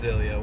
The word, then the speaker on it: Ilya: and my name is Ilya, once again, it's spelled I-L-Y-A, and there came a point in my life where I realized that I am not my name.